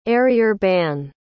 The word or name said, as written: arrière-ban